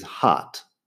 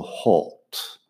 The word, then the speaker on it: hot: Yo digo hot es como una A en inglés americano. Hay gente que hace la A un, poco más extremo todavía, pero en inglés británico sería más bien algo como halt, con algo que, se, que se acerca más a una O. Halt.